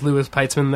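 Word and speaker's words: Louis Peitzman: My name is Lewis Peitzman there, which is my real name.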